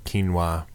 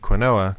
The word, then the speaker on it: quinoa: Which now I know my parents had to correct me, the like bleeding heart liberal, on the proper pronunciation of quinoa. – Oh, yeah. – Because I've been calling it quinoa, because that's how it's spelled. – Yeah. But it's a thing. – Yeah, having you my know, dad go, actually, it's quinoa, son.